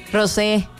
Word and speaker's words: Rose: Ella es Elena Rose. ¿O Rosé? ¿Cómo se pronuncia? – Rosé. – Rosé. – Cierto, Rosé. – Elena Rose. – No sé. – Rosé.